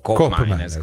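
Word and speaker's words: Koopmeiners: Qui parliamo fiammingo, che è l'olandese, per la cronaca colgo l'occasione per dire che di Cop Miners, la doppia O di Cop Miners invece si legge U.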